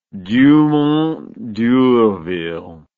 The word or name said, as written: Dumont d'Urville